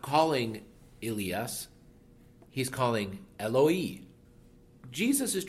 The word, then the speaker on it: Elias: The name Elijah in Greek is Elias, and it's pronounced in Arabic Elias and so forth.